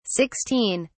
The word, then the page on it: 16: British English